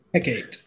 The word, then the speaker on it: Hecate: How do you say that? – Hecate. – Hecate? Mm-hmm. Really? That's how you say it? I've never read it that way. – I would say Hecate. – I think Hecate. I like Hecate.